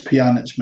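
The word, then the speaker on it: Pjanić: How much would we be willing to spend on, is it Pjanic? Have I pronounced that right, Colin? – It's Pjanic, mate. – Pjanic. – Yeah, just think of piano.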